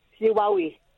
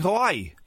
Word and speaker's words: Huawei: By the way, in the Chinese phone company, it's pronounced Huawei. Huawei. – So I'm saying Hawaii.